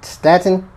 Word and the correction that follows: Statham: I just love to hear you just not able to be able to pronounce it. It wasn't right. – No, not at all. – What the fuck was it? – Statham. – Oh, Statham. Yeah.